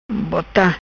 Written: Bota

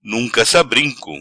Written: Nunca sa brinco

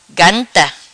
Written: Ganta

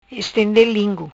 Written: Estende lingu

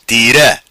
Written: Tira

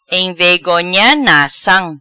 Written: Envegonha nacam

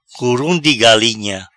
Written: Curum Di Galinha

